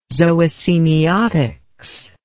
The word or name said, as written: zoosemiotics